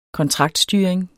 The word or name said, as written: kontraktstyring